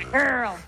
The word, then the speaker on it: GRRL: And I like it that it's spelled G-R-R-L. Grrr. – Yeah, girl.